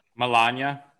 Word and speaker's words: Melania: How about these Melania tapes? Melania? Fuck, I always forget how to say it. Is it Melania? – Melania. – Melania. – Yeah.